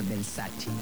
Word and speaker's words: Versace: Por ejemplo, Versace, cuando la marca Versace es italiana, pues se tiene que pronunciar Versace.